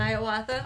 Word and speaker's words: Hiawatha: How do you pronounce it? – Hiawatha. – Oh, it is? Really? – Yeah.